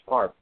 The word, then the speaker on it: Favre: Speaking of quarterbacks going into the Hall of Fame, there's a guy in 2 weeks, I mentioned him, number four, his name's Favre, that's how it's pronounced, Favre.